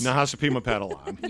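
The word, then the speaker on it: Nahasapeemapetilon: Nahasapima Padalan.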